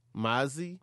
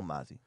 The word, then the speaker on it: Mozzy: Mozzie? – Yeah. – How you spell that? – M-O-Z-Z-Y. – M-O-Z-Z-Y. I feel like I should know that. – You know Mozzie.